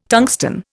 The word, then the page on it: tungsten: Indian